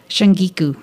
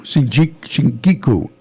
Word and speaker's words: shungiku: Shunjiku? How do you pronounce it? – Shungiku. – Shungiku.